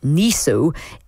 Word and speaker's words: NESO: Now, a whistleblower shared the report exclusively with the Times newspaper, and it says that the National Energy System Operator, pronounced NESO, isn't accurately able to forecast energy needs because of this rapid expansion of wind farms and solar panels in everybody's houses.